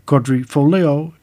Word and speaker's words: Quadrifoglio: Quadrifoglio is how to say Quadrifoglio.